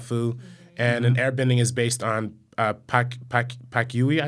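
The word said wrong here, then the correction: Pa Kua: Pakua, there you go.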